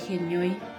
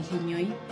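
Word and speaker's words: Henói: De Genioi en el Facebook. Genioi se escribe, es una palabra en guaraní. Se escribe H-E-N-O-I latina.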